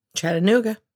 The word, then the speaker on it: Chattanooga: Is that how you say it? Chattanooga? – Chattanooga.